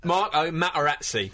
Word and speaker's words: Materazzi: Can we please pronounce the T's in his name? – Marco Materazzi.